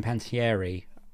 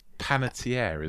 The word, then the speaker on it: Panettiere: They try at one point, try and make you believe that Hayden Panettiere, still naming her name wrong, is the killer. – Panettiere, isn't it? – Thank you.